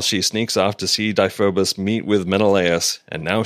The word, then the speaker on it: Deiphobus: Trojan commander Diphobus. I forgot how it's pronounced now. You know, I'm So, yeah, who cares? – okay. Trojan commander Deiphobus comes in and vaguely accuses Xena of being a spy.